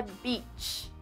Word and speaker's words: beach: Então, praia em inglês é beach. O xingamento é bitch. Repita comigo: beach. Não repita comigo, beach. Beach, beach.